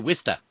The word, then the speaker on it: Worcester: Right, because I had to be taught how to say Wista. For Worcester. – Wista.